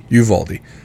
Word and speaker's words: Uvalde: Uvalde.